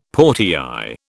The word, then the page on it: portiÊre: British English